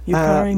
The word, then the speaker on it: Yukari: So a Japanese illustrator, and that is, and I want to pronounce this correctly, is it Yukari? – Mm-hmm. Yukari Mishima.